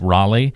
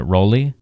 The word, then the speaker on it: Raleigh: someone's saying Raleigh, but they're pronouncing it Roley? – Oh, no, that's horrible.